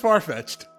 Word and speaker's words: Farfetch'd: It's just Farfetch'd. – It's Farfetch'd. – No, it's just Farfetch'd. – There's no E. It's apostrophe D.